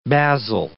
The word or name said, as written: basil